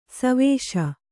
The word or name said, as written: ಸೇವಿಸು